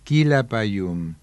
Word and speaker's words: Quilapayún: Buscaron un nombre indígena que tuviera acento en la última sílaba y se les ocurrió Quilapayún, que en araucano significa tres barbas.